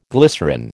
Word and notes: glycerine: American English

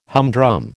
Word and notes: humdrum: American English

